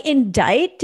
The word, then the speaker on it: indict: So many pronunciations I've learned from SVU, like indict. Did you know that bitch has a C in the middle of the fucking word? – It is tricky.